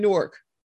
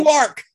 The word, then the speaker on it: Newark: It's not like, Newark. – Yeah, it's not Newark. – There was when people from Jersey say it sounds like just one syllable.